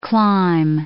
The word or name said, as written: climb